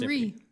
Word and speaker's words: Henri: So, in other words, somebody's name like Henry, which would be spelled with a Y, is an I in French and pronounced Henri.